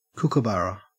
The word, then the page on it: kookaburra: Australian English